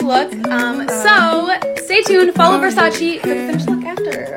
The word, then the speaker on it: Versace: Like, basically, she got her makeup done at the Versace show and made a video about it for the Versace account, and I'm pronouncing it the way that it's supposed to be pronounced, apparently, which is Versace, but she pronounced it Versace.